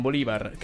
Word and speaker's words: Bolívar: Che non è Simón Bolívar. – Non è Bolívar, no. Ma Bolívar, proprio Simón con l'accento Bolívar. Sulla I.